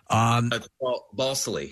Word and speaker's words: Balsillie: And you play a character and a real life man, Jim Balil. I hope I'm pronouncing that Basile. – Balsillie. – Balsillie. Okay, can't get that right.